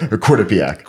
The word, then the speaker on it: Quinnipiac: And so, what was the transition like from your high school to Quinnipiac? How do you say it? – Quinnipiac. – Quinnipiac.